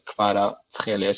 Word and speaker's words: Kvaratskhelia: Yeah, and his last name is Kvaratskhelia, same kh that pronounces as a ch, not as a h or something like that. It's like Kvaratskhelia, so you can split it in half, so it works easier.